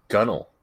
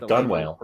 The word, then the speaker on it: gunwale: It's pronounced Gunnel. This is the technical pronunciation of the word. – If I can get it looks like Gunwale, but it's pronounced.